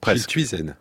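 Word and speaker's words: Schilthuizen: Menno Schillutsen. Je prononce bien son nom ou pas? – Schillutsen.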